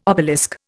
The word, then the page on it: obelisk: Indian